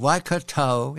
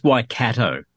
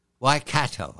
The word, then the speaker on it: Waikato: Hamilton, the University of Waikato in Hamilton, which is near Auckland on the North Island. Have I said it correctly? – It's Waikato. – Waikato. I'll learn it.